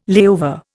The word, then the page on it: layover: Indian